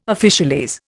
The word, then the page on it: officialese: Indian